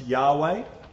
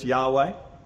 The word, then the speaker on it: Yahweh: No vowels in Hebrew, but most likely pronounced Yahweh. No, like, affectation or anything. Yahweh, I say, but no, just Yahweh. That's how you'd say it.